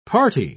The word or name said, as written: party